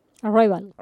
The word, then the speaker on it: Arrival: Arrival.